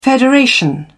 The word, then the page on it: federation: British English